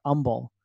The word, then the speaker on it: Humble: Like, right outside of Houston, there's a town called Humble. It's spelled with an H, spelled with a, you know, whatever. It's spelled with an H, but we all call it Humble. Instead of Humble, we call it Humble.